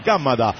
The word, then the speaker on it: Kamada: Casi todos le dicen Kamada, pero hoy escuchamos en una aplicación que me acercó Germán García Groba cómo lo pronuncia él, y es Kamada.